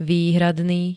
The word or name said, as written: výhradný